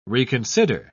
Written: reconsider

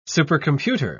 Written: supercomputer

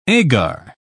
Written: agar

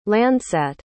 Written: landsat

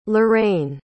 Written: lauraine

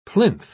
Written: plinth

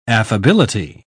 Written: affability

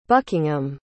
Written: buckingham

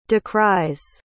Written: decries

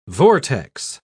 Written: vortex

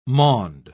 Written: maund

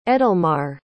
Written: edelmar

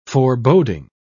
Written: foreboding

